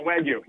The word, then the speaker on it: Wagyu: And he said that there's a place in Tampa that is like has the best – it's Wagyu beef, right? That's how you say it? – Yeah Wagyu.